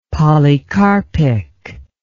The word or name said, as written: polycarpic